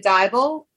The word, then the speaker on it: Dybel: Our prior guest, Tanya Dybel, rhymes with Bible.